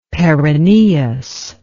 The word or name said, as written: peroneus